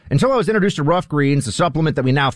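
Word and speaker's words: Ruff Greens: But now with Rough Greens, and that's spelled just like it sounds, R-U-F-F, it is perfect for dogs.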